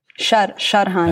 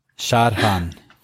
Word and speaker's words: Sharhan: Did I say it right? – Sharhan. – No. Yes. No. Sharhan.